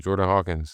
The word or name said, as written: Jordan Hawkins